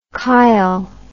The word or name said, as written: chil